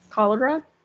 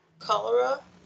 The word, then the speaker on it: cholera: But, yeah, so, in the love in the time of, how did you pronounce it? – Cholera. – Cholera.